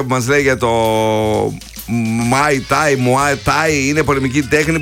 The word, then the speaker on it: Muay Thai: Muay Thai. – Muay Thai, το πείσαν την εταιρεία με τα ρούχα.